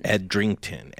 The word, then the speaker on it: Edrington: Did I say that right? – Edrington. – Edrington. Yeah.